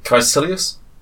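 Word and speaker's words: Kaecilius: Caecilius? – That's well pronounced.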